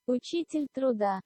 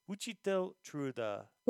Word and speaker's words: Учитель труда: And the proper pronunciation of it is? – Учитель труда. – Учитель труда.